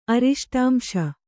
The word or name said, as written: ಅರಿಷ್ಟಾಂಶ